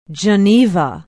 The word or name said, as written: Geneva